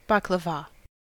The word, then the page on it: baklava: American English